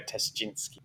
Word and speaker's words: Ptaschinski: P-T-A-S-C-H-I-N-S-K-I. So I reckon the P is silent. So I'm just going to go Tashchinsky. Okay.